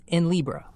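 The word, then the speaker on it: Enlibra: in Libra. – It's a Latin word, two syllables, in, to move toward, and Libra, balance, to move toward balance.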